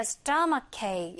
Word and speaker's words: stomachache: 자, 그랬더니 여자가 I have a stomachache. 요거 약간 스펠링도 좀 어렵고 복잡해 보이는데 요 부분과 요 부분 둘 다 크로 발음이 나는 거예요.